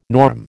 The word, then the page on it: norm: American English